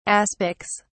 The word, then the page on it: aspics: British English